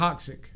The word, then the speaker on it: coccyx: Have you seen how they pop the cock itch? – No, I don't know what that means. – The back, where the butt is. – The coccyx.